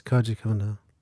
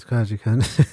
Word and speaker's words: Koji Kondo: Kondo, I believe the pronunciation is Koji. Kendall. – No, it's Koji Kondo. – Oh, yeah. Koji Kondo? – That's Koji Kondo.